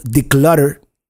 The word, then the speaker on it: Decluttr: And let me show you, because it is spelled in a weird way. Okay, here we go. It's spelled D-E-C-L-U-T-T-R. So it's missing a couple of vowels there. It's called declutter.